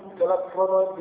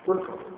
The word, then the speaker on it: تلفظ: وفي بعض الأصول تلفظ بتاء مثنى من فوق وفتح اللام والفاء مشددة تلفظ كم تلفظ الإسلام.